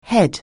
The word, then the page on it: head: anglais